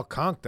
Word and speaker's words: conch: It was all conch down in Key West. – Conch. I always like that word. – Yeah. It was correct. I saw that. That's how it's properly pronounced.